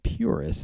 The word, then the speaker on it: PVRIS: So what I've got queued up for you now is a band called Puris. It's spelled P-V-R-I-S.